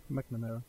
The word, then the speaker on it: McNamara: Og så er der en Heather Duke, som bliver spillet af Shannon Dorothy, og så en Heather McNamara. Er det rigtigt udtalt? – McNamara.